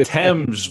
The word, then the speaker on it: Thames: The Thames, Brad. I was on mute. Thames.